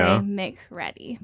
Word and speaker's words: McCready: Yes, that's how, when I tell people how it's spelled, I say McReady. But did then sometimes ever, they forget the extra C.